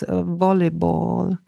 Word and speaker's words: volleyball: It needed somebody to tell me how to pronounce volleyball, because it's too hard. The V we pronounce like W, too strong for British. It's a soft vowel consonant in the beginning.